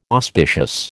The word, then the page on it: auspicious: American English